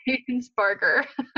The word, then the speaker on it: Rainsbarger: Rainsparker. – How do you spell that? – Where you spell it exactly as it sounds. It's A-R-A-I-N-S-B-A-R-G-E-R. – Awesome. – Rainsparker. Not Rainsberger. Not Rainsparger. Rainsparker.